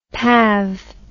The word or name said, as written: pav